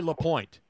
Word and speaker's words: Lapointe: No, there's no E. The E is silent. You leave it off. Guy Lapointe.